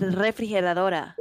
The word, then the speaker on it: refrigeradora: I guess no, it's refrigeradora. No, he messed it up. It's refrigeradora. – They actually both work though, right? – Refrigerador? – Yeah, in Necro they both work, yeah.